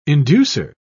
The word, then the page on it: inducer: British English